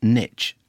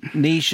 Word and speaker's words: niche: Mr. Banks pronounce niche as niche, as in itch, rather than the correct pronunciation as in niche and quiche.